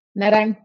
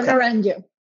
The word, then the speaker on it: Naranjo: How did you say your last name? – Naranjo. Okay. Or Naranjo. – Astrid Naranjo. Is that somewhat right? – Yep.